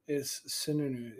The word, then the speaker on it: Sununu: How many syllables is it? Is it two new news or one new? – It's Sununu. It's Sununu. Two news. – Sununu. – It's one Sue and two news.